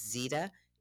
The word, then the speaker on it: Zeita: Tune in tomorrow morning at 8 o'clock because I would like to talk to you about, I believe her, the way you pronounce her name is Zeita. It's Z-E-I-T-A Merchant.